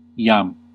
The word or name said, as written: maintenant